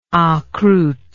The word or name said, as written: encroute